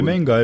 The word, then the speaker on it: Demenga: Demenga mı okunuyor? Demenga evet ben okunuyor. öyle biliyorum.